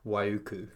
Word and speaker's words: Waiuku: And what town was it? – Waiuku. – Waiuku? North or South Island? – North Island. – North Island, okay. – And you pronounce that beautifully.